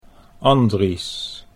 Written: Andries

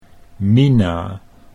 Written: Mina

